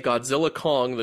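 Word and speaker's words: Godzilla x Kong: I learned that the X is silent. The director, Adam Wingard, confirmed this. You are just supposed to say Godzilla Kong, the new empire.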